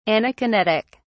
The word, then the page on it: anakinetic: British English